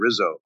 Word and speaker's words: Rizzo: Okay, and your name is ANACLETO, Anacleto and Rizzo, RIZZO, is that right? – Yep.